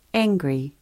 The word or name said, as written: riri